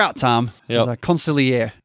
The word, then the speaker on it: consigliere: I mean, good consigliere. – Oh, that was the right way to say it. You got to have it the E at the end. Yeah. Consigliere.